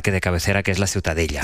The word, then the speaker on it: Ciutadella: La Ciutadella. – Ciutadella.